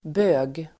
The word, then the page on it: bög: [bö:g]